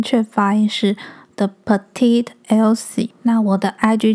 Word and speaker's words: Petite: 我之前呢一直以为我的品牌是念成 The Petite Elsie，然后我今天不知道哪根筋想到，就是我去搜寻一下 Petite P E T I T E 的正确发音，结果我发现它的正确发音是 Petite。